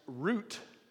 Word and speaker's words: Ruth: The two sons marry Orpah and Ruth, and the name Orpah means gazelle, and the name Ruth is pronounced root. And if you know it, it still is pronounced that in different languages today, which means friendship.